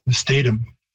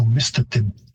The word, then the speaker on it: mistatim: You've got the A being pronounced like A as opposed to A, which it is in Cree. And you've got a stress shift because English tends to like, many words are stressed on the second last syllable, so you get mistatum. And the Cree stress or pitch system would have a higher pitch on the first syllable, so mistatim.